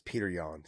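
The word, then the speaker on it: Petr Yan: He also had a win over Piotr, however you want to say his name. It's Peter Yan, okay, people? – There you go.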